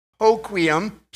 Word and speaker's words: Hoquiam: One is how to pronounce it, Hoquiam.